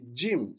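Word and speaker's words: Jim (ج): Et attention, c'est pas Jim, d'accord? Certains prononcent Jim, non, c'est Jim. C'est pour ça que dans la phonétique, ici, j'ai mis le D et le J.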